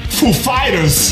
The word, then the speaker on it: Foo Fighters: He said, he asked us if the accent was on Foo or Fighters. We said, the accent is on Fighters, actually. So he goes up, he's like, ladies and gentlemen, Foo Fighters.